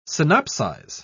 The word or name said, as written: synopsize